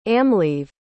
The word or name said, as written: amhlaoibh